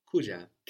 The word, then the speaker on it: kuja: Another important verb, like kula, with just one syllable after ku, is to come, kuja, K-U-J-A. – Kuja. – Kuja. I'm coming now. – Nina kuja sasa. – Nina kuja sasa. Are you coming? – Ye una kuja. – Ye una kuja.